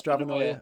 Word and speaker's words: Stravanoia: Eh, do you pronounce it Stravanoia? Is that is that the correct pronunciation for it? Like uh, paranoia, yeah, but Stravanoia.